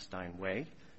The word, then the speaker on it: Steinweg: So in the Steinway case, the defendant used the name Steinweg when pronounced as directed by the defendant's managers, should be pronounced Steinway, repeatedly referred to the pianos as German Steinways, and even they put the name on the pianos themselves.